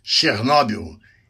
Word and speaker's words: Chernobyl: Em russo, pronuncia-se Chernobyl.